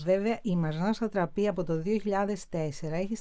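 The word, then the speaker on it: Marjane: Εγώ την έλεγα τόσα χρόνια Μαριάν. Είναι Μαρζάν. Είναι Μαρζάν.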